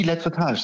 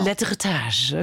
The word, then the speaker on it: Lettretage: Tom, habe ich das überhaupt richtig ausgesprochen? – Die Lettretage, sehr gut. – Die genau. Lettretage, okay.